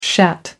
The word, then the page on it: shat: American English